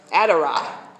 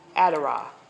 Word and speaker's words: Addereth: The mantle worn by Elijah is the Hebrew word in Strong's Concordance, Adderah is spelled A-D-D-E-R-E-T-H. Adderah. A-D-D-E-R-E-T-H.